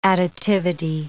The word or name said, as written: additivity